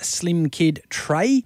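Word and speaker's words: Slimkid3: That's MC Slim Kid 3, or pronounced Slim Kid Trey.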